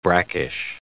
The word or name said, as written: brackish